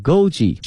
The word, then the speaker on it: Goji: Goji berry 啊， Goji G-O-J-I 跟我们汉语的发音是差不多的。 Goji berry 啊， G-O-J-I 后面加一个 berry B-E-R-Y 啊，这个词合到一起呢叫做枸杞啊， Goji berry。